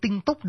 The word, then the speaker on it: tinh túc: Thì nhưng mà vì đi chung với chữ tinh, tinh túc đọc hơi khó đọc cho nên từ xưa người ta bỏ chữ C ở chót đi, đọc là tinh tú, viết tiếng Hán xuống vẫn còn viết đúng như vậy, viết là tinh túc và đọc là tinh tú.